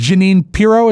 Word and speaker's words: Jeanine Pirro: They had Judge Jeanine Pirro. Is that how you say her name? – Yes.